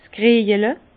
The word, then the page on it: 's Kriejele: Alsatian; Bas-Rhin; Herrlisheim